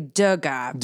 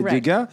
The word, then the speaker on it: Degas: Well, it's actually Degas. – En français, alors, c'est Degas. Mais en anglais, Degas, perhaps, right? – Well, initially, when he was born, his name was D-E space G-A-S, which means from Ga.